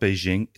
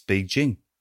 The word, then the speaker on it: Beijing: And they rang me once and saying, it's not Beijing, it's Beijing. – Some Chinese dude or some Brit? – No, just some guy saying, no, you know, this is the BBC way of saying it.